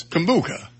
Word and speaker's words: kombucha: I always pronounce K-O-M-B-U-C-H-A. You know what that is? – Kombucha.